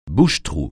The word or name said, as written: bouche-trou